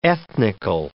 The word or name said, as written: ethnical